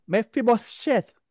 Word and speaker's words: Mephibosheth: Mephibosheth. I break it down. It's correct. – Mephibosheth.